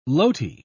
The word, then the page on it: loti: British English